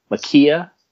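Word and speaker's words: Maika: Is it Makaya? It's like M-A-K-I-A. Makaya. Sure.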